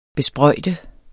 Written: besprøjte

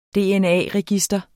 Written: dna-register

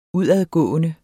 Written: udadgående

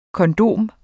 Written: kondom

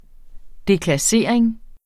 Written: deklassering

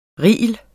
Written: reel

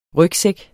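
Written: Rygsæk

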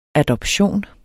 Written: adoption

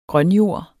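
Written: grønjord

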